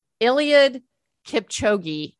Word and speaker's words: Eliud Kipchoge: So having just watched the Olympics, and I watched, I'm going to say his name right, Eliud Kipchoge, who is the guy that won the men's marathon in the Olympics, dominated.